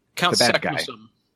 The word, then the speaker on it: Saknussemm: Count Saknussemm.